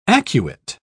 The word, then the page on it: acuate: British English